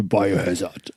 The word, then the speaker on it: Biohazard: Du hast es nicht ganz richtig ausgesprochen. Man sagt nämlich Biohazard.